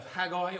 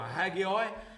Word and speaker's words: Haggai: It's Haggai or Haggai as far as I'm concerned.